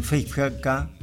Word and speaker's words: Feifke: Decíamos el líder de la banda, el pianista, conductor, arreglador, compositor, Stephen Fechka, así se pronuncia, tiene apenas 29 años.